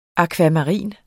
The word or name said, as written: akvamarin